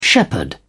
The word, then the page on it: shepherdess: British English